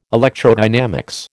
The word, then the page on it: electrodynamics: American English